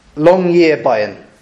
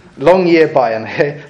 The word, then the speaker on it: Longyearbyen: There's a town in Norway called Longyearbyen. I'm pretty sure I said that right. Longyearbyen. Longyearbyen.